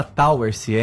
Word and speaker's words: Towersey: É, se escreve Towersey, mas se fala Towersey, é inglês.